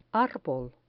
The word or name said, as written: arbol